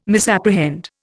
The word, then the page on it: misapprehend: Indian